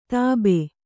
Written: ಧೋಬಿ

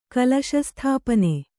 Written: ಕಲಶಸ್ಥಾಪನೆ

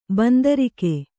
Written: ಬಂದಾರಿಕೆ

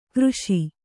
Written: ಕಾರೀಷ

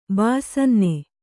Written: ಬಾಯ್ಸನ್ನೆ

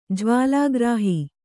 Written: ಜ್ವಾಲಾಗ್ರಾಹಿ

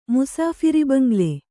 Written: ಮುಸಾಫಿರಿಬಂಗ್ಲೆ

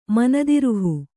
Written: ಮನದಿರುಹು

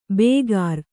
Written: ಬೇಗಾರ

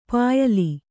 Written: ಪ್ಯಾಲೆ

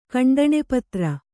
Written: ಕಂಡಣೆಪತ್ರ

